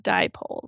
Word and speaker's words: dipole: So here's the thing about dipole. It has two syllables, di and pole.